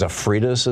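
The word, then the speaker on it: Stavridis: I watched on Chris Hayes' show the former supreme commander of NATO forces, General, I think it's Zafridis is how it's pronounced, during Obama.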